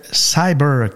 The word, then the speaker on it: CYBR: Evolve a aussi un FNB du genre, le Cyber, qui s'écrit CYBR,